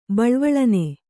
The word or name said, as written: ಬಳ್ವಳನೆ